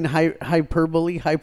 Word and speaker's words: hyperbole: Like saying hyperbole. Okay, that's how it's spelled. – Hyperbole. – I used to say hyperbole.